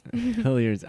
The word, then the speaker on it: Hilliard's: We went to Hilliards. Did I say that right? – Yes. – Hilliards after.